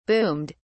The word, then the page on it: boomed: British English